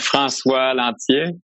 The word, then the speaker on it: Francois Lanthier: Francois Lanthier.